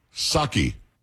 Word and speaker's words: Psaki: Psaki. – Psaki? – Psaki. It's not Psaki. It was just Psaki. You didn't pronounce the P.